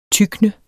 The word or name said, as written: tykne